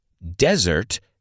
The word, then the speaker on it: desert: Desert se pronuncia desert. No te líes con dessert, postre, que se pronuncia dessert.